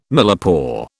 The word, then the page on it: millepore: British English